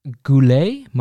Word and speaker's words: Goulet: It's French, but it's Goulet, Marie Goulet. – How is it spelled? – It's Marie Odile Goulet-Cazet. – Marie what? Odile? – Odile. Goulet. Like go. G-O-U-L-E-T. – Goulet. – Goulet, right? Mm-hmm. And Cazet? And then Cazet. Yeah, K-A-Z-A.